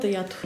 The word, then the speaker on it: ято: Ято от изкуства, като думата ято. – Да, ято, но неговото той поставя ударение да. Ято да.